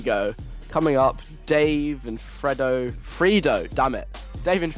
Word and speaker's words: Fredo: Before that, Dave and Fredo Funky Friday. I finally said it right, yay.